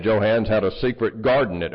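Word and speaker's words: Johanns: Mike Johans is or Joe Hans. Am I saying it wrong? I thought it was Hans, but it's Hans. Hans. Get your hands off of there.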